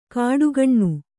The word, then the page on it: ಕಾಡುಗಣ್ಣು: kāḍu gaṇṇu